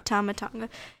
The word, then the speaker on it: Tama Tonga: Then we have Randy Orton and Kevin Owens versus the new Bloodline, Solo Sikoa and Tama Tonga. Is that how you say his name? – Tama Tonga.